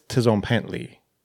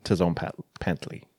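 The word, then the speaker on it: tzompantli: I think it's Tizompantli. I think you say the T. I don't think it's a silent T. I think it's like Tizon Pantley.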